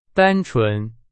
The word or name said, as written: 单纯